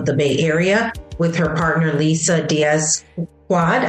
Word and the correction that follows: McQuaid: Did I say it right, Quad? I think McQuaid. – So. McQuaid. Okay. Yeah, close. Okay. McQuaid. Lisa.